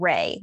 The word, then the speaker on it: Ray: So Raz was born in 854 AD in the city of Ray, which I did look up, and that's how you say it, which is south of modern-day Tehran, which is the capital of Iran.